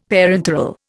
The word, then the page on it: parenteral: Indian